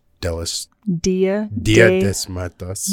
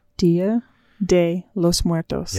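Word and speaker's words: Día de los Muertos: Dia de los Muertos. What? – Yeah. – Dia de los Muertos.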